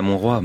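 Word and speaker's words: Mont-roig: Montreuil, pour Miro. – Oui, à Montroi, Montreuil, ou Montroi en français, oui, c'est ça.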